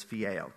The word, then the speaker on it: phileo: I'd like to say phileo, but I butcher names, so the real pronunciation is phileo.